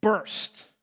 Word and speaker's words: Burst: Funny thing, my last name, very hard to mispronounce, very German, Burst, like burst a bubble.